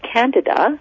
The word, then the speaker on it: Candida: So we say, in the alternative holistic world, we say Candida. But if you're talking to a conventionally trained doctor, they call it Candida.